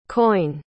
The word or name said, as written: coyne